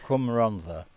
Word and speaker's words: Cwm Rhondda: Cwm Rhondda.